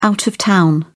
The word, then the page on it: out-of-town: British English